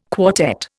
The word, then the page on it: quartet: Indian